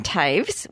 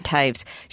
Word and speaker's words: Toews: Her name's Miriam Taves, which is spelled T-O-E-W-S. Miriam Taves.